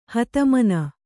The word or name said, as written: ಹತಮನ